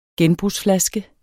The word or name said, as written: genbrugsflaske